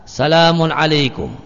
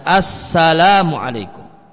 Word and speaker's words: assalamu alaikum: Ini ucapan salam tidak sah menurut Syafi'iyah jika salamun alaikum. Ini. Maka jika ini, maka tidak sah kalau bacanya salamun alaikum. Harus ada alif lamnya sehingga assalamu alaikum.